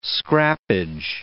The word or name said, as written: scrappage